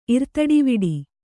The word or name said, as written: ಇರ್ತಡಿವಿಡಿ